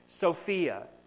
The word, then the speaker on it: Sophia: The word wisdom in Greek is the word Sophia. Literally, that's exactly how you say it. Sophia.